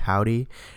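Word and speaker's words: Hovvdy: Before Stormzy, we heard from the band Howdy. They're a band, the way it's pronounced is spelled with two V's.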